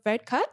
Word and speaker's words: Varadkar: Boris Johnson met with Leo Varadkar.